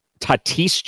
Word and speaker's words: Tatis: Tatis Jr. is how you say it.